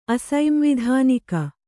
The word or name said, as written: ಅಸೈಂವಿಧಾನಿಕ